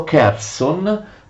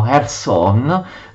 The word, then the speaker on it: Kherson: Per questo i bizantini inviano Costantino, detto Cirillo, che diventerà, tutti lo sapete, il famoso evangelizzatore degli slavi, presso Cherson, presso Kherson, che era la capitale dei Khazari, Kherson che gli ucraini pronunciano Kherson, la capitale del, appunto, del Khan dei Khazari.